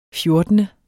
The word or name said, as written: fjortende